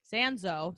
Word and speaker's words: Sanzo: Uh, Kimberly Sanza. I pronounced her name wrong on the episode. Sanzo, excuse me, I pronounced it wrong again. Here it is. Sanzo and I both said how we use our dog as like positive reinforcement.